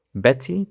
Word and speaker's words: Betty: Betty también se puede pronunciar Betty. – Como mucha gente lo va a pronunciar Betty.